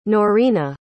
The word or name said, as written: Norina